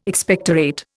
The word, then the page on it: Expectorate: Indian